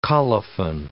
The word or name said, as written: colophon